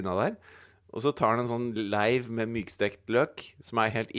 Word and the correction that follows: sleiv: Hva leiv? – En sleiv. – Sleiv, ja. – Bare feiluttalt.